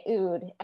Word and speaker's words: oud: And is it pronounced oud? – It is, yep. – Okay, oud.